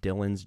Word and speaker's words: Dylan: It's called Dylan's Journey. My name is Dylan, not Dylion, like a lot of people accidentally spell it, but it's Dylan's Journey on YouTube.